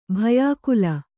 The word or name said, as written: ಭಯಾಕುಲ